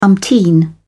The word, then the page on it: umpteen: British English